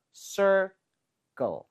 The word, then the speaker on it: circle: r 발음할 때 sir, 혀의 코어가 뒤쪽으로 당겨져요. sir 했다가 go 할 때는 혀가 윗니와 입천장 사이를 눌러야 돼요. circle.